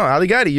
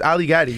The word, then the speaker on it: Ali Gatie: No, Ali Gatie, you got it. It rhymes. Ali Gatie, you know? – It does rhyme. – That's the easy way to remember.